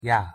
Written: ㅑ

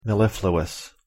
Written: mellifluous

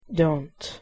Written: dont

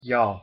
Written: ㅕ

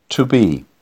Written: to be

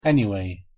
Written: anyway

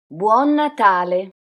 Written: buon natale

